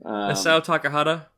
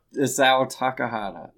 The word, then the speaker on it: Isao Takahata: Isao Takahata. – Isao Takahata.